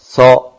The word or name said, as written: so